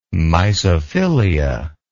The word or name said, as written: mysophilia